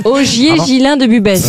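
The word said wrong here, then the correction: Busbecq: Augier Gillin de Busbeck, pardon, j'ai écorché son nom. – Il me semblait bien aussi. – Ça, c'est ça, oui. Est-ce que vous pouvez appeler Busbeck? – Busbeck. Augier Gislain de Busbeck. – Ah, Gislain.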